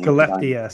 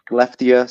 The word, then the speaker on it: Skellefteå: Skellefteå, Sweden. – Skellefteå, Sweden.